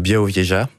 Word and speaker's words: Białowieża: Et voilà, c'est la forêt, c'est la fameuse forêt de Białowieża, ouais, à l'est de la Pologne, à la frontière biélorusse.